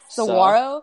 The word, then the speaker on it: Saguaro: Saguaro. So. There you go. Sa- you Saguaro. It's, instead okay. – of G U A, it's W A R.